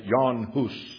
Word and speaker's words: Jan Hus: His name was John Huss, or as some pronounce it, Jan Hus.